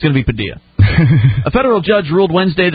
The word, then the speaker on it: Padilla: I know he's a name's allegedly pronounced Padilla, but I just can't bring myself to do that. – Well, it is Padilla.